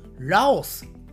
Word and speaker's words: ラオス: はい、えー、 1 つ目のね、英語の発音がほぼ同じ発音で、日本人はラオスと言います。ラオス、ラオスと言います。はい、そしてこのラオスの首都、キャピタルはこちらです。